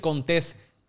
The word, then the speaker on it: Contés: Mi nombre es Miguel Contés con acento en la E.